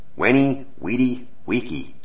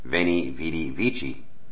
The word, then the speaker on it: Veni, Vidi, Vici: Of course, once I got home, I would hear my mom pronounce it, Veni, Vidi, Vici. It wasn't because I was necessarily taught wrong, or did my mother necessarily mispronounce on her end, but the fact is we were speaking two totally different dialects of the Latin language. You see, she was reading Veni, Vidi, Vici in ecclesiastical Latin, or for short, Church Latin.